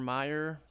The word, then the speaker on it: Mayer: Oscar Mayer, like the hot dogs. Mayer is M-A-Y-E-R, not M-E.